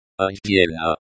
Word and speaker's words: отдельно: Отдельно. Pronounced like отдельно, stress on the second syllable.